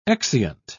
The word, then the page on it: exeunt: British English